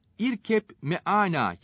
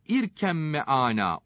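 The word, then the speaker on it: İrkemme ana (ارْكَبْ مَعَنَا): Burada, irkep meana yazılır ise de, İrkemme ana okunur.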